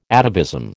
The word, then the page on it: atavism: American English